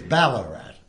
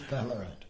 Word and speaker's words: Ballarat: The other fellow, he says Ballarat. It's Ballarat.